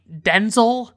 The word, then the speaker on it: Denzel: She was in that with Ryan Reynolds and Denzel Washington, who apparently pronounces his name Denzel. And he like said this on like a late night talk show, and he just like doesn't correct people or something.